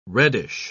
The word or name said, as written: reddish